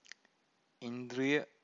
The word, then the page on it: indriya: Pali